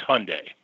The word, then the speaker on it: Hyundai: They say that it rhymes with Sunday, so it should be Hyundai. I tend to say Hyundai, but it's Hyundai.